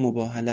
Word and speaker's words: مباهله: و آیه 61 سوره آل عمران که به آیه مباهله معروف است، میدانید که تلفظ صحیحش هم مباهله است،